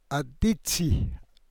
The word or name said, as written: adiidsii